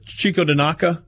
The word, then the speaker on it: Chikaodinaka: Here's how his first... I don't even remember. Chico Danaka, I think, is how I pronounced it. – That's pretty good. – Rolls C-H-I-K-A-O-D-I-N-A-K-A.